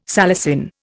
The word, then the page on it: salicin: Indian